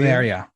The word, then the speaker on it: Sverige: Oh, is that how you say it? Sverige? – Sverige, yeah. I'm learning Swedish now because of that franchise.